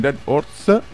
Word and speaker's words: Dead Horze: Ospiti con noi in Dead Orts. A me piace pronunciarvi così e penso sia anche giusto.